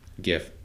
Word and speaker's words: GIF: GIF. – Okay. – Because it's spelled gift without the T.